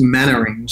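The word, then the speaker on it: Mainwaring: Well, it's, it's written Maine but it's actually pronounced Mannering.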